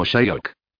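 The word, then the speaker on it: Shyok: Justo al norte de esa aldea se juntan los dos ríos que definen el valle de Nubra, el Nubra y el Shiok, dijo pronunciándolo como Shayok.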